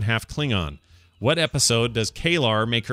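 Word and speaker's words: K'Ehleyr: It's Kaelor. – Oh, is it just Kaelor? Yeah. It's Kaelor. That looks like Kaelor.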